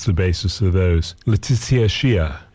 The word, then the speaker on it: Xiuh: That's Miss Letitia Shia. Her last name is spelled X-I-U-H, but I believe it's pronounced Shia.